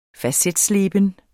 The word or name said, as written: facetsleben